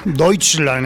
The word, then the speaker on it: Deutschland: Bueno, Deutschland, me han dicho. Joder, es que no se puede aquí tampoco. Ya me, ya me, han corregido mis, ah, profesores. Deutschland, hay que decir.